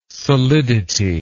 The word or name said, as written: solidity